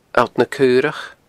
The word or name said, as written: alltnacaorach